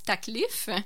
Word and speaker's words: Taklif: Pardonnez ma prononciation, mon arabe est totalement inexistant, mais je crois que ça se prononce Taklif. – Oui, je crois bien aussi.